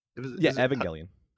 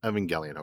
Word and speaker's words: Evangelion: Evangelion. – Evangelion, okay.